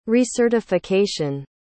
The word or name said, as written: recertification